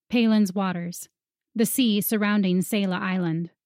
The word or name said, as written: Paelens Waters